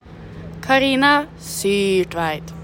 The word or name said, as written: CARINA SYRTVEIT